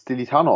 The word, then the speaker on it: Stilitano: Auf jeden Fall, mein Nachname wird Stilitano ausgesprochen. Also eigentlich genauso, wie man es schreibt. Aber tatsächlich wird das sehr oft falsch ausgesprochen. Aber Carmine Stilitano.